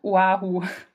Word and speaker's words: Oahu: Die hawaiianische Sprache, die sieht ja immer so kompliziert aus, ne? Aber die haben genau eigentlich nur die Vokale, die wir haben, und es wird genauso ausgesprochen wie das Deutsche, also Oahu.